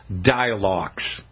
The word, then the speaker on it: Dylox: One of them is called Dilox. It's spelled D-Y-L-O-X.